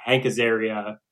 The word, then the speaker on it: Azaria: It just doesn't, because it actually says why Hank Azaria, is that how you say his name? – Yeah.